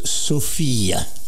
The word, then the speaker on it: Sophia: Je précise d'abord, son prénom se prononce Sophia, que ce soit avec un F ou PH, modernisé en PH. Et Sofia, c'est la capitale de la Bulgarie.